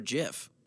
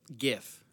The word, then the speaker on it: GIF: Do you say gif or jif? – Gif. – Good. I don't care what anyone says. I don't care what the inventor of the GIF says. It's pronounced GIF.